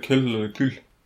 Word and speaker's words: Kohl: Ja, Helmut Kohl ska det väl uttalas. Eller kul.